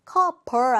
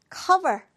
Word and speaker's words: cover: 자, V의 발음을 살려서 발음해 보세요. Cover. Cover 아닙니다. B 아니고 V니까요. Cover.